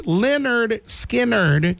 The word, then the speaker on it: Lynyrd Skynyrd: Lynyrd Skynyrd, their debut album, pronounced Lynyrd Skynyrd, containing Skynyrd classics.